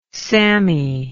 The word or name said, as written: sammy